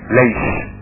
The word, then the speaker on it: ليش: بس دايما اليهود ينطقوا السين زي موسى. موسى يقولك موسى. يسوع يقولك ايه؟ يشوع. ليش.